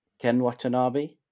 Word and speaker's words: Ken Watanabe: I loved, I hope I pronounce this right, Ken Watanabe. – Yes.